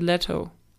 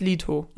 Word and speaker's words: Leto: Die sagen aber Jared Leto. Leto. – Ja. Leto. So, und äh, ich habe mich ja informiert und der heißt Leto. Und die ja. haben es alle falsch gesagt.